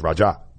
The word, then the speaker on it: Roger: And so the way they pronounce Roger in the Virgin Islands is Raja.